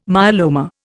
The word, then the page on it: myeloma: Indian